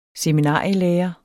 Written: seminarielærer

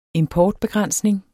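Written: importbegrænsning

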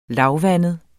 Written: lavvandet

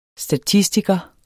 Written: STATISTIKER

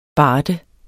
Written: BARDERNES

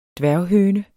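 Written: dværghøne

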